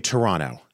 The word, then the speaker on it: Toronto: People from Canada say Toronto. – Yep. – Not Toronto. – Correct. – What do they say? Toronto? – Toronto. – Hot. I love it. – Yeah. – Silent T.